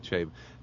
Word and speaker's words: Chabon: Chabon. – Okay, Chabon.